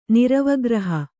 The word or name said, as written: ನಿರವಗ್ರಹ